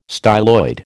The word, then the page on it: styloid: American English